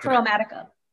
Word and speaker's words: Chromatica: Chromatica. I just can't talk. – Chromatica.